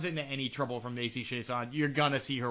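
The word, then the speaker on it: Chiasson: I love that you are giving the proper French pronunciation to Macy Chason.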